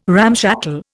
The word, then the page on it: ramshackle: Indian